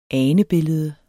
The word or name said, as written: anebillede